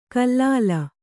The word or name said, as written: ಖಲಲು